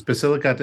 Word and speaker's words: Basilicata: Yes, it's Basilicata in Puglia.